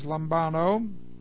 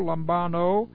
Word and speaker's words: lambano: Here's lambano. L-A-M-B-A-N, long O, lambano, which does mean just that.